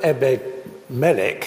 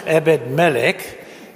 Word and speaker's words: Ebed-Melech: I'm not sure how many contacts I have on my phone, but I'm certain that Ebed-Melech, thank you, Adrian, for teaching us how to pronounce it, Ebed-Melech is not on it.